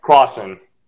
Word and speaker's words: Closson: Is it Closson? Am I saying that right? – Closson. – Closson, golly, dude.